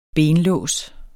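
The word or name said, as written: BENLÅS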